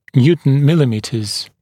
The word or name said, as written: Newton millimeters